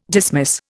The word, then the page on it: dismiss: Indian